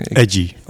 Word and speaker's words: EJJI: En zijn bedrijf heet, ik kan het niet eens uitspreken. – Edgy. – Oh, Edgy. – Ja. – Ja, E-J-J-I.